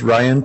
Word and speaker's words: Ryan: Yes, my name is Ryan Pillar. Last name is spelled P as in Paul, I-L-L-A-R, first name R-Y-A-N.